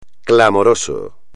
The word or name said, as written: clamoroso